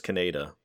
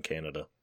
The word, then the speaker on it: Kaneda: Yeah, because his name was Kaneda, but the other voice actor apparently couldn't pronounce it that way and kept calling him Canada the whole time.